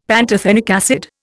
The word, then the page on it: pantothenic acid: Indian